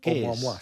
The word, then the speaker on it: Oumuamua: Y hoy nos has sugerido que hablemos de Oumuamua. ¿Lo he dicho bien? – Sí. Oumuamua, sí.